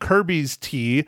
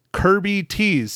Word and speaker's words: Kirby Tees: Now I said it wrong last week because uh, I didn't want to type shit in, but it's not Kirby's T. It is Kirby Tees.